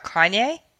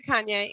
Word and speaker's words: Kanye: Are you talking about Kanye? – What did I say? – Kane? – I met Kanye, and he goes by Ye now.